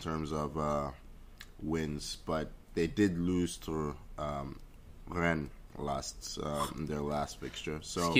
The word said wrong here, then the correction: Rennes: That's not how you say it, Tony. – Rennes. – There we go. Roll your R's.